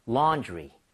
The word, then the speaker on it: laundry: 세탁물, 빨래 이것을 바로 laundry라고 하는데 A-U-A-W는 발음할 때 O예요? 어예요? – 어. – 어에 더 가까워요. 완전 어는 아니지만 이 모양을 O보다는 와. 벌려야 됩니다. 그래서 laundry. laundry가 아니에요. 자, 가봅니다. laundry.